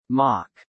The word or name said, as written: mock